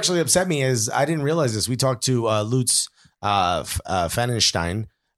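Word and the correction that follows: Pfannenstiel: Is that close? – He is so proud he knows how to pronounce that name. – Yeah. It's Fennenstiel.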